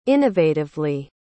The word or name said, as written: innovatively